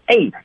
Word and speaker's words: ate: 我们先来看它的尾音是 ate，我们都知道 ate，咱们 PK 台讲过很多次了，它有至少三种词性，当它做动词的时候要读成的是 ate，当它做形容词的时候要读成 it，做名词的时候呢应该读成的是 it，所以这儿大家一定要注意它的发音，因为它是个动词，只能发成的是 ate 这个音了。